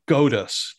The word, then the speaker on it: GOATUS: VDH is the GOATUS, G-O-A-T, us. So greatest of all time, rhyming with POTUS, kind of mixing there.